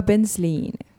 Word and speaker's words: Penzlin: tere tulemast saatesse, Inga Bensliin. kas ma ütlesin õigesti või? – jaa, nii see on.